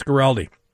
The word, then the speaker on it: Guaraldi: That's the correct way to pronounce the name, right? Guaraldi? – I believe so, yeah. – Yeah, Vince Guaraldi.